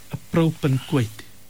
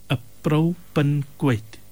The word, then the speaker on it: appropinquate: But the word that we were looking for today, Monana, is the word appropinquate. – What? – Appropinquate. It's spelled A-double-P R-O-P-I-N, pin. That was the answer to the second clue. And then Q-U-A-T-E, apropinquate.